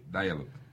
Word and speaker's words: Dialogue: ¿así se pronuncia? – Sí, Dialogue. – Dialogue, oye, lo pronuncié bien. – Qué bueno. – Oye, fíjate que Dialogue. – Ya se está preparando para el viaje. – Antes decía Dialogue, a los sabaneteros, ¿no? Ahora digo Dialogue.